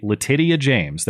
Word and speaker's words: Letitia: And well, it wasn't over because remember our favorite state attorney general, New York AG Letitia James. That is the proper pronunciation of her name, as we learned then.